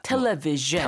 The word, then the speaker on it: television: 주도 아니고 즈도 아니고 어. 그 사이에 주주주. – 주. – Like my pleasure. – 어. Television. – Television. – 어. – 그 주 발음이에요. – 아 이게 슈 하고 주 하고 중간쯤 중간에. 되는 발음 되겠습니다.